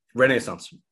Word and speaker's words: Renaissance: Renaissance. Nailed it. – Did I really? – Yep, that's how you pronounce it.